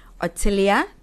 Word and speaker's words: Othilia: My name is really the way you say it is the way you spell it. Otilia Tutu Mungoba, O T H I L I A Tutu T U T U Mungoba M U N G O B A.